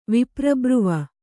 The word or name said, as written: ವಿಪ್ರಬ್ರುವ